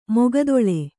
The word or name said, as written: ಮೈಗತ್ತಲೆ